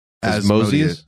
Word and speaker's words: Asmodeus: Asmodeus.